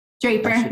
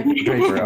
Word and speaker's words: Draper: Draper. – Draper.